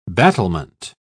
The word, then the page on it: battlement: British English